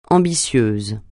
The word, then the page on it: ambitieuse: francais